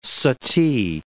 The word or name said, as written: suttee